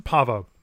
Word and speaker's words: Pahvo: The discovery sends Michael Burnham, Ash Tyler, and Saru to the planet Pahvo. Is that right? Yep. Pronunciation Pahvo in hopes of using a naturally occurring antenna to amplify a signal that will help Starfleet detect a cloaked Klingon ship.